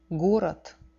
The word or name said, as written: gorod